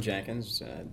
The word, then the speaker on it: Jenkins: Dylan Jenkins. It's J-E-N-K-I-N-S.